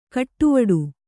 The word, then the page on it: ಕಟ್ಟುವಡು: kaṭṭuvaḍu